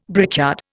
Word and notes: brickyard: Indian

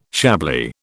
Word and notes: Chablis: British English

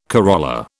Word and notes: corolla: British English

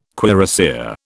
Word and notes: cuirassier: British English

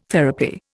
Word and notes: therapy: Indian

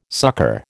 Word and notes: succor: American English

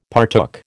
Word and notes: partook: American English

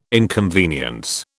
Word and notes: inconvenience: British English

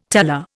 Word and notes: teller: Indian

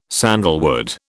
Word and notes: sandalwood: British English

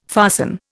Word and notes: fasten: Indian

